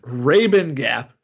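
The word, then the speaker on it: Rabun Gap: Rubbin Gap is how it's pronounced.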